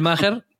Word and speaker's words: Maher: Real Time with Bill Mangel. Pues Bill Maher, que se escribe Maher, creo que si tenéis HBO lo podéis ver.